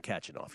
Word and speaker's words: Khachanov: Karen Khachanov.